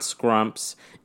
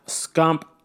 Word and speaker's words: Scump: Before jumping into the last bit of the segment here, I do want to point out that, yes, I did mispronounce Gump's name last week. I will hold myself accountable on that. It is not Scrumps. It is Seth Scump Abner, pro Call of Duty player, and I will put some respect on his name.